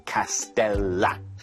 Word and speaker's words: 카스텔라: 하지만 솔직히 R 아니야? L이요. 그래서 카스텔라. 발음은 카스텔라. 네.